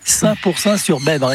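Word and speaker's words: Saint-Pourçain-sur-Besbre: L'ai-je bien dit? – Parfaite, parfaite êtes... diction, bonne prononciation. Oui, Saint-Pourçain sur Bèbre, exactement.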